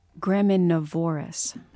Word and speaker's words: graminivorous: The emphasis was on the wrong syllable. Graminivorous.